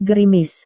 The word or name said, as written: gerimis